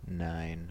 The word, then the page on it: nine: /ˈnaɪn/; [naɪn]